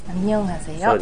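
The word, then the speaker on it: ア ンニ ョン ハ セヨ (안녕하세요): アンニョンハセヨ。 – そうですね、アンニョンハセヨですね。これは一つ間違えてね覚えやすい発音っていうのがあって、アンニョンハセヨっていうのがあるんですけど、アンニョンハセヨじゃないんですね。アンニョンハセヨと最初のアンっていうところで舌を噛むような感じですね。アンニョンハセヨということになりますね。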